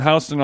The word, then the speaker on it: Houston: I was walking down on Houston on the way here. Yeah. See how I pronounce it? That's how you know.